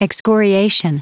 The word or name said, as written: excoriation